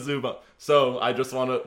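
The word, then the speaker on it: Zubac: It goes like Ivica Zubat, like P.S. – Ivanka Zubat. – Like, like T.S.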